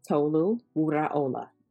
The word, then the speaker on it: Tolu Wuraola: My name is Dr. Tolu Wuraola.